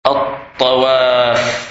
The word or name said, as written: Tawaf